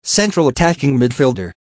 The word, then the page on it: central attacking midfielder: American English